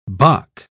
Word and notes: bock: British English

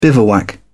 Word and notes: bivouac: British English

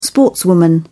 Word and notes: sportswoman: British English

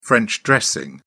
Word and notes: french dressing: British English

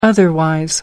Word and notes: otherwise: American English

